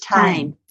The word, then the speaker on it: Tame: How do you say it? Grace Tame? – I think it's Tame.